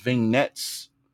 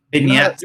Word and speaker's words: vignettes: They were playing these vignettes. I don't know. How do you say that word? – Vignettes.